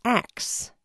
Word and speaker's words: ask: It's not uncommon to hear that word pronounced axe. To many, that's a mispronunciation, but as NPR's Shireen Marisol Meraji reports, axe is as old as English itself.